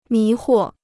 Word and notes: 迷惑: mí huo

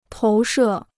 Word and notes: 投射: tóu shè

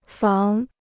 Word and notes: 防: fáng

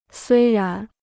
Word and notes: 虽然: suī rán

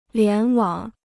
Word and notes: 联网: lián wǎng

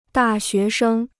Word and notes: 大学生: dà xué shēng

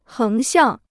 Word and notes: 横向: héng xiàng

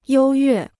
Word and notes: 优越: yōu yuè

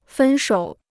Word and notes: 分手: fēn shǒu

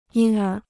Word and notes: 因而: yīn ér

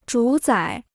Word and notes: 主宰: zhǔ zǎi

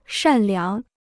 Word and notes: 善良: shàn liáng

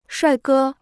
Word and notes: 帅哥: shuài gē

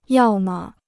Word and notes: 要么: yào me

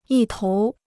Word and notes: 一头: yī tóu

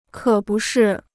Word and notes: 可不是: kě bu shì

